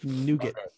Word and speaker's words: nougat: It's nugget. – It's nougat.